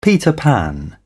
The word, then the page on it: Peter Pan: British English